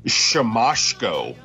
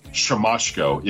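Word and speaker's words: Siemaszko: and it's Shamashko, which I would have never. – Wow, really? Yeah. – Shamashko, yep.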